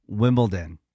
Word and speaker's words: Wimbledon: It's D-O-N. – Like Downton. – Okay, hold on. Say it correctly again. – Wimbledon. – Wimbledon.